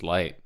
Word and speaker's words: lighght: And it's off his 2014 record, Light, spelled L-I-G-H-G-H-T. – And how do you know that it's pronounced Light?